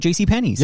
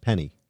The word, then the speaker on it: JCPenney: That's why you might hear one for a GMC truck, or you might hear one about one in Spanish about JCPenney's. – Yeah, JCPenney. – Oh, is that how it's said? – Penny. Penny. We did this. Penny. It's just singular.